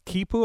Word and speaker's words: quipu: Now, they had this, it's called quipu, am I saying that right? – I think so, yeah.